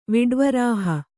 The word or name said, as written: ವಿಡ್ವರಾಹ